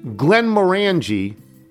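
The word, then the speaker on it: Glenmorangie: Glenn Morangi rhymes with orangey.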